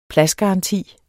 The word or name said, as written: pladsgaranti